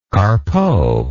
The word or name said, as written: carpeaux